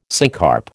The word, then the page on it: syncarp: American English